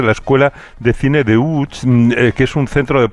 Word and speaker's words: Łódź: Aunque se pronuncia Łódź, se escribe Lodz, por si alguien lo busca Lodz, en Google.